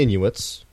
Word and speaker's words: Inuit: So Eskimo is technically world music, you know, only sort of because of the fact that, well, it's based around the Inuits, which were real people. I may have said that wrong. – No, I think you got it.